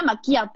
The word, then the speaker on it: Macchia: Y además suena Maquia todo el café.